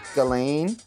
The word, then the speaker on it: Ghislaine: It's pronounced Ghislaine. Ghislaine? – Epstein Ghislaine Maxwell.